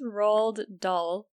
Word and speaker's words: Roald: It's Roald Dahl. – Oh, that's right. No, it's Ronald, isn't it? – No, it's Roald. – How do you spell that? – R-O-A-L-D.